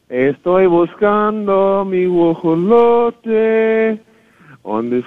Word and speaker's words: guajolote: Guajolote. – Guajolote.